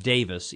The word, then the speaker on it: Davies: Well, his name is Michael Davis, but it's spelled D-A-V-I-E-S, but it was pronounced Davis, even though everybody during his life pronounced it Davies.